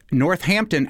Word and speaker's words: Northampton: Northampton has one H but pronounces two H's. North. Mm-hmm. Hampton.